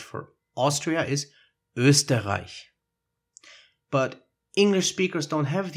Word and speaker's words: Österreich: So the Ö probably gets replaced with O, and the H with a K sound, because that's the pronunciation in some English words with the combination CH, like in chemistry. So instead of saying Österreich, they will say Österreich.